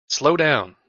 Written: slow down

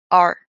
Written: AR

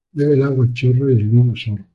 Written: chorros

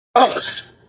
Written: Us

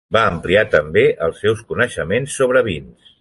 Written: coneixements

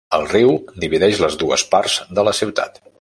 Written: divideix

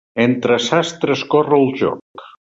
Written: corre

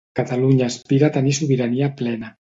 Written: aspira

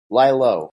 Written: lie low

